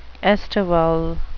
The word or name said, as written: estival